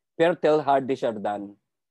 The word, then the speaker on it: Pierre Teilhard de Chardin: My message is that uh, I come across with this um, philosopher who's, who's also a priest, Pierre, Pierre Teilhard de Chardin.